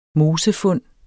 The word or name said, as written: mosefund